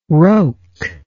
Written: roke

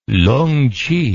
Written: lungchi

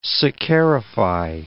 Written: saccharify

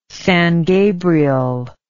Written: San Gabriel

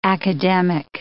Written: academic

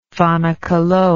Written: fanakalo

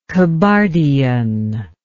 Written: Kabardian

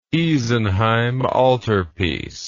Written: Isenheim Altarpiece

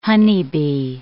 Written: honeybee

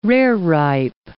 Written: rareripe

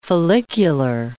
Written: follicular

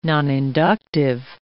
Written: noninductive

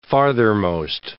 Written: farthermost

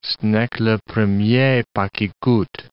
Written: ce n'est que le premier pas qui coute

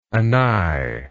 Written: anigh

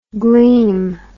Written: gleem

